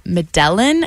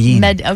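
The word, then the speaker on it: Medellín: It's a song called Medellin, I think is how you say it. – Medellin. – Okay, there. So, not even close.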